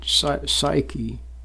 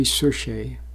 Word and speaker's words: psuche: Some people pronounce it psyche, but actually it should be psuche.